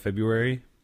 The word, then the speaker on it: February: You're the only person I've ever met that pronounces the R, like the silent R, in February.